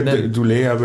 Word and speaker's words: Keir Dullea: Keir Dullea würde ich auch sagen. Das hat mich fast so sehr fasziniert wie der Film 2001 überhaupt. Keir Dullea, einer der beiden, die sich ja sehr ähnlich sehen, die dann am Ende in dem Raumschiff sind und Herr gegenüberstehen.